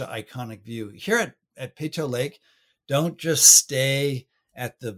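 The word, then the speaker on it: Peyto Lake: You run into Peto Lake again, or Peto Lake, however they pronounce it.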